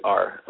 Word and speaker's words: Schaefer: So if you Google Mark Schaefer, it's Schaefer, like the beer, Schaefer beer.